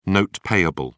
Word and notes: note payable: British English

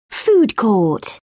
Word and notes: food court: British English

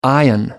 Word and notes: ion: British English

